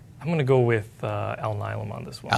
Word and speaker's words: Alnylam: Intuitive Surgical or Alnylam Pharma. Did I say that right? – Alnylam.